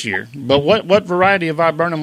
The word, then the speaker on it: viburnum: I bought a viburnum, and I hope I'm saying that correctly.